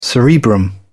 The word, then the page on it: cerebrum: British English